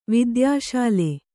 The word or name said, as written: ವೈದ್ಯಶಾಲೆ